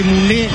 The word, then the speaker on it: Muné: El 7 Muné es con acento en la E. – Obvio, sí. – Como Coné. – Como Coné, el de Condorito. Este goleador, es el goleador de la campaña, Muné.